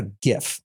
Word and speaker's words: GIF: When I go to tidal.cloud, do you have a GIF? Yes, that's how it's pronounced.